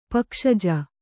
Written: ಪಕ್ಷಜ

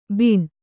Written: ಬಾಣ

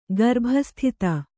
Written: ಗರ್ಭಸ್ಥಿತ

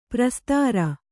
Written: ಪ್ರಸ್ತರ

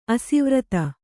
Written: ಅಶ್ವರಥ

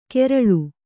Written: ಕೆರಳು

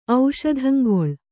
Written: ಔಷಧಂಗೊಳ್

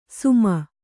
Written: ಸುಮ

